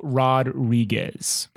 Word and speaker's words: Rodriguez: Um, if you want to pronounce his full name properly, it's Paul Rodriguez.